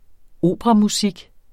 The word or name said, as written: operamusik